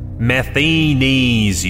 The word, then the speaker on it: Matheny: "Matheny's, you asshole. It's a long E sound.